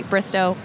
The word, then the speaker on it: Bristow: K A R I, Kari Bristow, B R I S T O W.